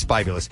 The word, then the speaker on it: bibulous: But you check the pronunciation, and it's bibulous.